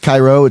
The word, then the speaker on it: Cairo: It's spelled C-A-I-R-O, but it's not pronounced Cairo. It's pronounced, I believe, Cairo.